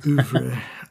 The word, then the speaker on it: oeuvre: But yeah, it's sort of a weird part of his oeuvre. I actually said it right for the first time in my entire life. Normally I say oof.